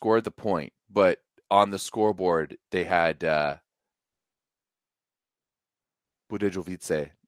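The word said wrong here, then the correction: Budějovice: up by, did I say that properly? No. – No. – How do you say it? – Budejovice. – Budejovice. Budejovice. – Yeah, don't pronounce the J.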